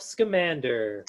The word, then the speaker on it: Scamander: And she also recently, and I'm going to pull out my notes now, met someone on her adventures, Rolf Scalamander. Am I pronouncing that last name right? – Rolf Scamander. Scamander. Grandson of Newt Scamander.